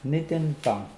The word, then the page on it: nidinpoang: Mokilese